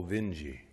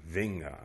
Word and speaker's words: Vinge: His father's name was Axel Vinji, we pronounced it over there. V I N G E, or Vinga, I suppose you'd say, huh?